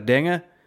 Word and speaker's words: Denge: And yes, indeed, I have a very difficult last name. It's properly pronounced as Denge, but it's a bit much.